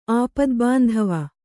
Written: ಆಪದ್ಬಾಂಧವ

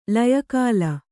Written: ಲಯಕಾಲ

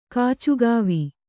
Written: ಕಾಚುಗಾವಿ

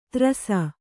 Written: ತ್ರಸ

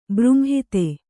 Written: ಬೃಂಹಿತೆ

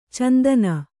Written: ಚಿಂತನೆ

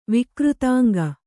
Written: ವಿಕೃತಾಂಗಿ

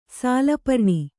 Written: ಸಾಲಪರ್ಣಿ

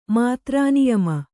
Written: ಮಾತ್ರಾನಿಯಮ

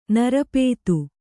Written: ನಿರುಪಾಧಿ

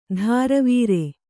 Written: ಧಾರವೀರೆ

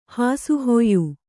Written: ಹಾಸುಹೊಯ್ಯು

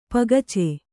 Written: ಪಗಚೆ

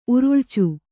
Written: ಉರುಳ್ಚು

